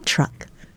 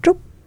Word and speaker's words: Trup: It's just pronounced differently here in the U.S. because people have a hard time saying Trup. So Tan Trup, that's my name. And so here they say Truck, and I respond to it.